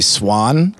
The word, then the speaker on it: Suahn: So it's me, and then Dak Daniels is direct support, and then my homie Swan, it's spelled S-U-A-H-N, Swan.